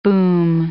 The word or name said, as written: boom